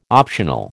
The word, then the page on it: optional: American English